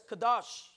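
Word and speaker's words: kadosh (קדוש): Consecration is pronounced in the Hebrew as kadosh.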